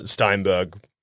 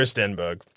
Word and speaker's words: Stenberg: And the reason I wish I paid more attention is because I know Amandla Steinberg from other movies. – Yeah. – Okay. – Steinberg. Where? Don't know how to say her name. – That's okay. – Sorry, Amandla. I'm going to go Stenberg.